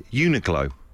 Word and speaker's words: Uniqlo: A shop whose name I've only just been able to pronounce recently, Uniqlo.